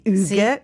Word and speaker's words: hygge: Allora Hüge. Si scrive H-Y-G-G-E, Hüge.